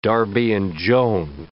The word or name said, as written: Darby and Joan